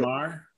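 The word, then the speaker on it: Fermor: Farmer.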